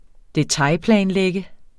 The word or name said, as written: detailplanlægge